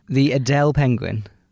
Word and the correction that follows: Adélie: It's spelled A-D-E with an apostrophe L-I-E. – Adélie. All I hear is Adèle.